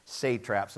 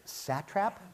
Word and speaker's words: satraps: And that's the right way to pronounce that. So, Cheryl, 20 points. Um, at the beginning, because it looks weird, right? Satrap? Satrap. At the beginning, Paul will probably correct me later, it is not satrap.